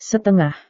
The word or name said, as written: setengah